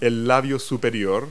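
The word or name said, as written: upper lip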